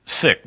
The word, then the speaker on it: Sikh: S-I-K-H, I think is how it's spelled. She actually pronounced it sick,